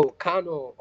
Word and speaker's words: Kano: What what about Kano? Kano? How do you say that? Is that in the desert? Do people live there or go there? – Oh, Kano. Oh yeah, Kano.